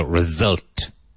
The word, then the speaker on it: result: Result. Not result. Result. Result. And even though it's spelt with an S, there's a Z or a Z sound in there.